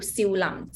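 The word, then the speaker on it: Siu Lam (少林): That is Siu Lam, three tone.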